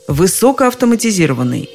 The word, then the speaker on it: высокоавтоматизированный: Правда, одно из них основное, а другое как раз в первой части «высоко», так называемое побочное, более слабое – Таким образом, в слове «высокоавтоматизированный» основное ударение приходится на «и», а побочное – на «о». Итак, высокоавтоматизированный, высокоамперный, высокоавторитетный, высокобелковый, высококачественный.